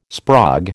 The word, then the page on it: sprog: American English